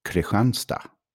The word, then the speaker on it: Kristianstad: There's another cool city down south, and it is spelled Kristianstad. – Okay. – Kristianstad. So, like Christian city. – Mm-hmm. – But in southern Sweden, they pronounce it Kristianstad.